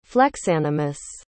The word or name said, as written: flexanimous